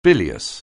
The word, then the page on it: bilious: anglais